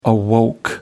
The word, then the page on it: awoke: American English